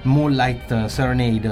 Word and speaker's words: Moonlight Serenade: come questo appunto di Moonlight Serenade, non so se è giusta la pronuncia, maestro mi dica lei.